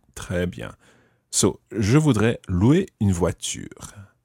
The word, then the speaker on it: voudrais: Je voudrais louer une voiture. – Okay, in this one, you wouldn't pronounce the S of je voudrais because it's not followed by a vowel.